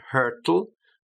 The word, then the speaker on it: hurtle: And just be careful with the pronunciation, hurtle. Okay, and the spelling, H-U-R-T-L-E.